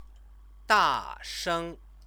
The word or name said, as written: dasheng